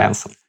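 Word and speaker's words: Branson: Я Ричарда Брэнстон, или как-то не знаю. – Брэнсон.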